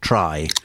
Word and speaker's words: Traigh: It's spelled Traigh, it's pronounced Traigh, and it means beach in Gaelic. So it's a Gaelic word.